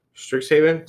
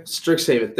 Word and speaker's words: Strixhaven: Strixhaven. Strixhaven.